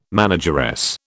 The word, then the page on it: manageress: British English